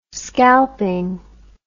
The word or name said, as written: scalping